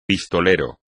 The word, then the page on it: pistolero: espagnol